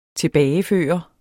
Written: tilbageføre

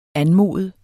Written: anmode

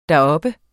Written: deroppe

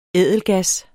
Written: ædelgas

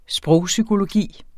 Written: sprogpsykologi

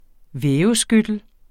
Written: væveskyttel

